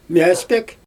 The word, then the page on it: mjAspEk: Xyzyl